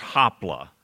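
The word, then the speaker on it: hopla (ὅπλα): Let me stop and say, the word weapon in our text in 2 Corinthians is pronounced hopla.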